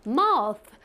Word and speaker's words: mouth: 이쪽으로 어떻게 바람을 쉬, 바람을 쉬 내보내면서 mouth, mouth, mouth 문제 없으시죠 여러분? mouth 아 입은 그렇게 발음하는구나 자 우리 여기 보시면 th 사운드가 이런 번데기 모양으로 된 것들 네 번데기 모양 mouth 이렇게 발음한다라는 거 기억해 주세요 자 또 마우스가 나오니까 제가 네자너자 당신의 눈을 떠주세요.